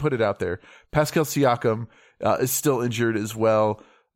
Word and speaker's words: Siakam: Pascal Siakam. – Siakam. – Did I say it right? – No. – Siakam. – You almost said it.